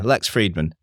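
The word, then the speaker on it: Lex Fridman: So, okay, popular podcast is a guy called Lex Friedman. Have I got his name right? Yeah, Lex Friedman.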